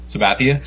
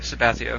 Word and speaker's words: Sabathia: First, pronounce CC's last name for me. – Sabathia. – Sabathia. Okay, do you have that? Sabathia. – Sabathia, sorry.